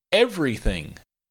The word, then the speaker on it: everything: And another word is everything. Everything is three syllables, not four, so be careful. The second e is not clearly enunciated.